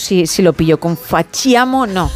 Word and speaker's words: facciamo: Fachiamo. – Solamente un, error que te corrijo desde mi bilingüismo. Vale. La CH es una K. Fachiamo. – Es que se escribe con CC, entonces yo me lo pongo como, como lo he escuchado.